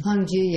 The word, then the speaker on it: Hongzhi: Hongzhi, yeah.